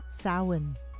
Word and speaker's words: Samhain: It looks like Samhain, spelled in English, Sam, S-A-M, Hain, H-A-I-N, but it is pronounced Samhain. I think I pronounced it incorrectly the first time. It's Samhain.